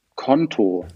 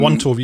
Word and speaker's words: Konto: Also es ist so, ähm, genau, tatsächlich Konto wird ausgesprochen Konto, also wie einfach wie das Konto. – Also nicht ähm, Quanto, wie ich es gerade gesagt habe.